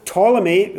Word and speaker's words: Ptolemy: Ptolemy, with a silent P, P-T-O-L-E-M-Y.